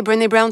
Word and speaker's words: Brené Brown: Brené Brown, ça s'écrit B-R-E-N-E et Brown comme la couleur marron.